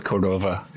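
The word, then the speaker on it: Córdoba: How do we pronounce Cordoba? Cordoba? – I go with Cordova. – Okay, I go with Cordova, too.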